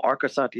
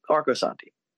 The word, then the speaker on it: Arcosanti: Well, Arcosanti is how I say it. So if we're saying it wrong, then we're saying it wrong. Arcosanti.